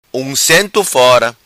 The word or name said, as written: Uncento Fora